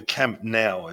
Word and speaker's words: Camp Nou: Or the Camp Nou, as they pronounce it, Barcelona Football Club.